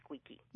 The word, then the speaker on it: Sqeeqee: Yes, I spell it a little bit uniquely, but yes, that's pronounced Squeaky.